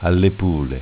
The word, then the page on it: Allepule: Dialetto